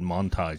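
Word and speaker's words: Manthey: Yeah, well, I asked him in German. I said, how do you pronounce it? Yeah. And he said Manti, just like it's Taycan, not Taycan.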